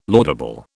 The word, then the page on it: laudable: British English